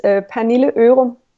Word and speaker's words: Pernille Ørum: Well, in Danish, it's Pernille Ørum.